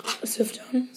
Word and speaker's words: Sufjan: Sufjan.